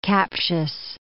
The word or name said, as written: captious